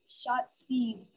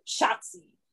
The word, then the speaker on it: Shotzi: She's it's like yelling Yahtzee, but Shotzi.